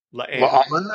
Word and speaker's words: La'an: So you feel sorry for Loan, or I can never pronounce her name. – La'an, I think, is how they usually.